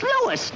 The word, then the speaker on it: Louis: Sounds like there might be a story at the plant, Lois. – Lois? Me name is Lewis, not Lois. Chief Wiz, everybody interpolates me name wrong. It's Lewis. L-O-U-I-S.